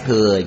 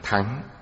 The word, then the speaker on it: thừa: Chữ này âm cổ đọc là thắng. Bây giờ mọi người đều đọc thừa. Bây giờ đọc thắng không ai hiểu. Chúng ta tùy tục mà dùng là đại thừa.